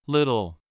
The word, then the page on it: little: American English